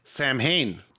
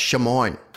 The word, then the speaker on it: Samhain: I've been saying that wrong my entire life because it's spelled S-A-M-H-A-I-N. – Samhain. – Yeah, and somebody once told me it was pronounced Shamoin. – Like what Michael Jackson would say. Shamoin! – No, it's pronounced Samhain for sure.